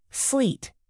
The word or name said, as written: sleet